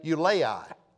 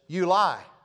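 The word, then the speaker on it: Ulai: I would say Eulai. But the computer, artificial intelligence, pronounces this Eulai.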